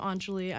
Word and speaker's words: Anjali: I'm Anjali.